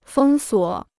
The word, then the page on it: 封锁: fēng suǒ